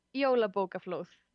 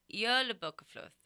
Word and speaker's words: Jólabókaflóð: Is it Jólabókaflóð or how do you pronounce that? – Yeah, Jólabókaflóð. – Jólabókaflóð.